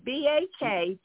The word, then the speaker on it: Baktamarian: for our listeners, I want you to, her last name is Baktamarian. So for me to be able to pronounce that, I hope I did it justice. I hope I did it well.